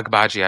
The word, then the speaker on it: Agbaji: Yeah, yeah, Agbaji, I believe.